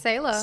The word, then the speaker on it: Selah: I think it's Selah. – Selah, okay.